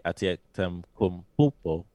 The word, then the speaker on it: Antetokounmpo: One of my favorite facts about this whole thing is all three Antetokounmpo brothers.